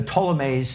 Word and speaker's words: Ptolemies: So the Ptolemies, Ptolemy is spelled the P is silent, P-T-O-L-E-M-A-I-S, the plural, Ptolemies.